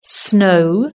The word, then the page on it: snow: British English